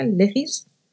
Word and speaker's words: legis: La analogía legis, que se escribe tal cual, así se pronuncia, legis, se aplica una norma concreta a un supuesto de hecho no exactamente comprendido en su contenido y se cuenta con dos elementos.